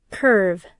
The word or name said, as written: curve